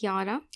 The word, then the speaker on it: Yara: My name is Yara. If you want to pronounce it properly, it's Yara, not Yada, not Yoda.